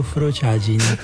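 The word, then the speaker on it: frociaggine: Stanno pronunciando tutti in modo sbagliato, non ho detto in questo modo, ho detto frociagine.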